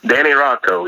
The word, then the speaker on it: Rocco: You were recruited by, am I pronouncing it right, Rocco? – Danny Rocco, yes, sir.